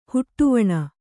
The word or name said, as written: ಹೆಡೆವಣಿ